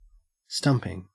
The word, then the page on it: stumping: Australian English